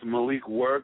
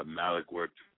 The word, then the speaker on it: Malik: It's Malik Work, but sometimes Malik Work flows off the tongue better, and as long as you spell it right, I don't really care.